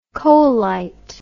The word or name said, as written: coalite